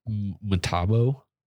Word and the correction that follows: Mtabo: So everyone says Matabo, but the way you'd say it is Mtabo.